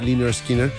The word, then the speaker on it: Lynyrd Skynyrd: Se pronuncia Lynyrd Skynyrd, es un disco sensacional, de verdad que recoge lo mejor de esa vertiente del country rock.